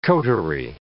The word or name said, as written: coterie